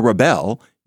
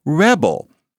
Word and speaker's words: rebel: You rebel against something, and you don't become a rebel, you become a rebel. But you would never say that you were going to rebel against something. Rebel, accent on the second syllable, verb. Rebel, accent on the first syllable, is a noun.